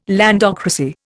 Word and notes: landocracy: Indian

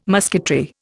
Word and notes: musketry: Indian

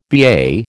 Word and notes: BA: American English

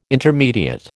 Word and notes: intermediate: American English

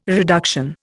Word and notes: reduction: Indian

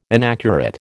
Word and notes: inaccurate: American English

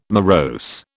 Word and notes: morose: British English

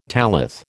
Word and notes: tallith: American English

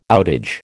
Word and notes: outage: American English

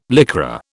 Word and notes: Lycra: British English

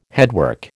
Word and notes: headwork: American English

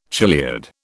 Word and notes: chiliad: British English

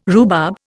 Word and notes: rhubarb: Indian

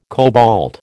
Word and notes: cobalt: American English

